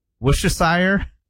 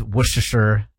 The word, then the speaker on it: Worcestershire: So you don't say the R? You don't say Worcestershire? – No.